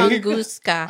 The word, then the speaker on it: Tunguska: It's like, Tunguska.